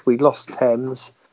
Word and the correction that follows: Thames: Or Thames, sorry. – Thames. – Thames, should say it properly, not the English way.